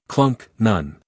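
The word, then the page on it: clunk: uk klʌŋk; en-us klʌŋk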